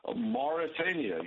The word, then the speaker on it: Mauritania: Tell me how you say Mauritania. – Mauritania, you said it correctly.